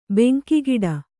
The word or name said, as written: ಬೆಂಕಿಗಿಡ